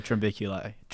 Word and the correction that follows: Trombiculidae: I'm trying to remember it. It'll come to me if I mispronounce it a lot of times in a row. It's a Trombiculidae.